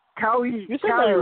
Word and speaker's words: Kawhi: Yeah, I'm trying to remember his name too. Keanu Leonard. – Kawhi Leonard. There you go.